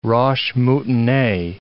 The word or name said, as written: roche moutonnee